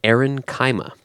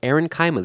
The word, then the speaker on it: aerenchyma: erenchyma. I think that's how it's pronounced. It's spelled A-E-R-E-N-C-H-Y-M-A. Erenchyma, erenchyma, erenchyma. Not sure. I'm going to say erenchyma.